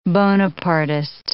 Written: Bonapartist

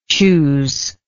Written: choosingly

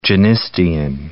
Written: genistein

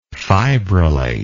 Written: fibrillative

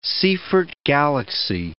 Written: Seyfert galaxy

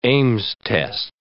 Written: Ames test

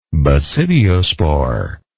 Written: basidiosporous

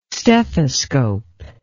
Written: stethoscope

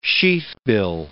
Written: sheathbill

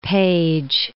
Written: Paige

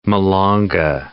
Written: malanga

